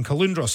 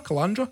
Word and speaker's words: Caloundra: I was unsure at first, confused by a pronunciation of Caloundra, Calandra, Caloundra, Caloundra. Calendra. Calendra. Yeah.